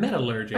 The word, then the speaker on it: metallurgy: In the script, she skipped over the term metallurgy.